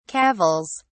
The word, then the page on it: cavils: British English